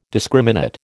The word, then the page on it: discriminate: American English